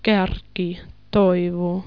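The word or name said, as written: kärki toivo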